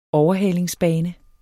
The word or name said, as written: overhalingsbane